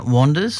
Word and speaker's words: Wanders: So it's Wayne Wanders. So it's W-A-N-D-E-R-S, as in walkabout.